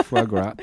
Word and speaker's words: foie gras: You had some good foie gras. Is that how you say it? – Yeah, foie gras.